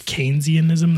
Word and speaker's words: Keynesianism: Keynesianism. That's how you pronounce it. – Yeah. Which okay. is cause I was saying Keynesianism. – Yeah. It's Keynes. It's John, okay.